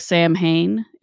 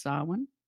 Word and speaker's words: Samhain: It looks like Samhain. It's pronounced Samhain.